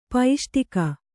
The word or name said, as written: ಪೈಷ್ಟಿಕ